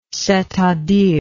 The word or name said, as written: c'est-à-dire